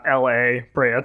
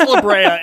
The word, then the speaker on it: La Brea: I really hope that it is La Brea Tar Pits and not L.A. Brea Tar Pits. – It's La Brea. It's not L.A. Brea.